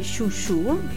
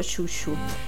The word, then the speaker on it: Xiu Xiu: E questa cover l'hanno fatta i Shushu, che non sono una razza di cane, ma sono una band californiana. E il nome è scritto Xiu Xiu, ma si legge appunto Shushu.